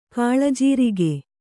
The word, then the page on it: ಕಾಳಜೀರಿಗೆ: kannada